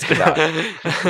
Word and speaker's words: Gadot: It's Gadot. It's probably... – The T is silent.